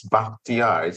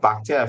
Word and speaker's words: Bakhtiar: Because my name is not easy to pronounce, Bakhtiar. It's Persian by origin. Mm-hmm. Bakhtiar, if any, means perfect choice.